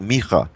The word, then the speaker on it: Micah: I think one of the best passages is in the prophet Micah, or Micah, sometimes people pronounce it,